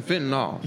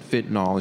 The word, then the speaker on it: fentanyl: I said fentanyl, and she was like, no, I think it's fentanyl.